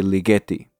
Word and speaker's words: Ligeti: Ligeti. I've heard people pronounce it in all sorts of ways. So I'm going to stick with Alex's pronunciation and say Ligeti.